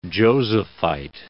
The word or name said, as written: Josephite